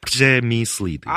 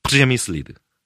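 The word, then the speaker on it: Přemyslid: 발음 잘 들어보십시오. 프레미슬리드. – 아! 미치겠다 진짜. – 그냥 영어식으로 하면 프레미슬리드. – 네. 예. 근데 정식은 어떻게 된다고요? – 프레미슬리드. – 프레미슬리드? – 예예 비슷합니다. 네. 보통 그냥 프레미슬리드라고 하는데 네네네. 그냥 프레미슬리드라고 하겠습니다.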